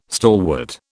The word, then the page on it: stalwart: British English